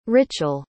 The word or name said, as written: Richael